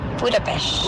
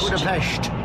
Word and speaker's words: Budapest: Budapest. – Budapest.